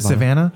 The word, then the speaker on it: Savannah: Am I pronouncing that right? Savannah. – I think so. That sounds right. – It sounds familiar. – You can say Savannah. – Is it Savannah? – Yeah.